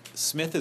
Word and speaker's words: Psmith: Smith is spelled P-S-M-I-T-H. Sorry? – He wrote down what you said, and then you said how to spell it, and he was like... – a silent P, like in Shaw.